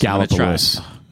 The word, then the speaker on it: Gallipolis: Gallipolis.